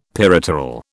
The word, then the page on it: peripteral: British English